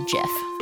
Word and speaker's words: GIF: It's a gif. – Yeah, the hard G.